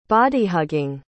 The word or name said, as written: body-hugging